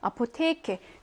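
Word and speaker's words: Apotheke: 이거 강세가 되게 뒤에 있어가지고 시험에도 자주 나오는데 아포테케 강세를 받는 애 다음에 자음이 몇 개예요? 한 개. 그러면은 어떻게? 길게. 아포테케 하고 길게.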